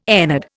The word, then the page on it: ennead: Indian